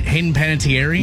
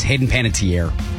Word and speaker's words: Hayden Panettiere: Am I pronouncing her name right? Hayden Panettiere? – No, it's Hayden Panettiere. – Tier. Dang it.